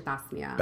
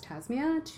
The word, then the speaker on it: Tasmia: And the actual way to pronounce it is actually Tasmia. – Tasmia. – And it's an Arabic name. And with the American accent, that's actually really hard to pronounce. And so I tend to always introduce myself as Tasmia to be accommodating, let's say. So that's how you say my name.